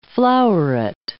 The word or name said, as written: floweret